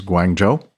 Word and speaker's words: Guangzhou: Guangzhou is Guangzhou. Chengdu, Shenzhen. Beijing's Daxing, D-A-X-I-N-G airport, P-K-X, excuse me.